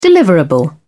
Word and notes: deliverable: British English

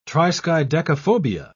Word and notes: triskaidekaphobia: British English

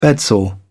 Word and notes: bedsore: British English

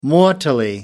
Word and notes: mortally: American English

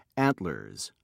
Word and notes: antlers: British English

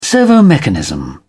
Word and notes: servomechanism: British English

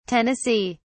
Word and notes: tennessee: British English

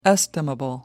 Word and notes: estimable: American English

